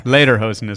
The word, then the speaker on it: lederhosen: Lederhosen is the proper pronunciation. – Excuse me, lederhosen.